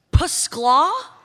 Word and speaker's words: Skyla: Pascla? Is that your name? – Yes. It's spelled S-K-Y-L-A. The Y is silent, and there's an invisible P at the beginning.